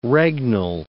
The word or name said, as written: regnal